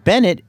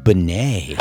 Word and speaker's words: Benet: His last name is pronounced Francais. It's not Bennett. It's LJ Benet.